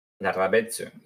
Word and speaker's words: Larrabetzu: Larrabetzu.